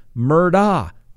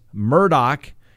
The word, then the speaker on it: Murdaugh: Now you may say, hey, you've been pronouncing this Murdaugh. That's right, I have. That's the way it looks like it's spelled to me. I've even watched news reports from South Carolina, and they pronounce it Murdaugh. But based upon the recordings that I heard from Alex Murdoch, that's the way he pronounces it, we're going to pronounce it Murdaugh from here on out.